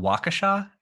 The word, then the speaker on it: Waukesha: So I was just like 2 weeks ago, I was in Waukesha. I think that's how you say it.